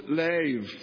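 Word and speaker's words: lev (לב): One of the main Hebrew words for heart in the Old Testament, you know this, is the word, it's spelled L-E-B, but it is pronounced lev, which is really my control center.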